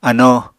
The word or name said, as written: ano